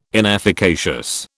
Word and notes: inefficacious: British English

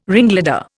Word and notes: ringleader: Indian

